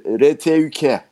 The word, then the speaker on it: RTÜK: RTÜK. Öyle okunuyor değil mi? – Evet.